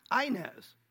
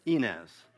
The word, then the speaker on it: Ynez: And my friend pronounced it Y-N-E-Z. Yeah, I think the shortest street is Y-N-E-Z. And I corrected him, and I said, it's pronounced Inez.